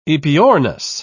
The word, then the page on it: aepyornis: British English